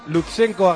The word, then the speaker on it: Lutsenko: La octava etapa de la vuelta a Suiza, hoy lo he dicho bien, Lutsenko ha ganado, Thibaut Pinot sigue como líder.